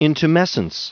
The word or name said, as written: intumescence